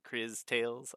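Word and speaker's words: Cris Tales: C-R-I-S. Chris. I pronounce it Chris Tales. I don't know if that's... – Wait, are you saying C-H-R-I-S? – C-R-I-S.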